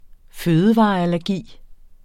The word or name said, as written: fødevareallergi